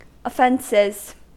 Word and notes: offences: American English

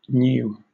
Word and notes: gnu: British English

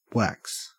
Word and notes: wax: Australian English

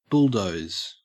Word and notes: bulldoze: Australian English

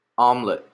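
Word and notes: omelette: Canadian English

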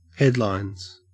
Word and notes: headlines: Australian English

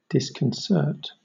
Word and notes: disconcert: British English

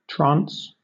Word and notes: trance: British English

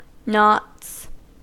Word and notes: knots: American English

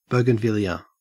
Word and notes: bougainvillea: Australian English

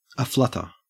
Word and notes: aflutter: Australian English

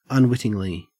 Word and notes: unwittingly: Australian English

